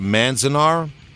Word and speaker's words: Manzanar: The one was in 2018, if I pronounce it right, Manzanar.